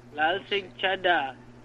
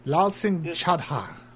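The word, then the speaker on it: Lal Singh Chaddha: Lal Singh Chadha? – Lal Singh Chadha. – Yeah. Lal Singh Chadha.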